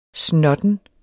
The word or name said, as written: snotten